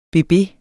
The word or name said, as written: bebe